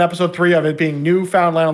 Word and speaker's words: Newfoundland: How does nobody on this show know how to pronounce Newfoundland? It's what? not Newfoundland. It's Newfoundland. What my response to you though was like, yeah, clearly this single word is said as three separate words.